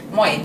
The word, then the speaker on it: Moi: Moi. – Genau. Moi wie das holländische Schön.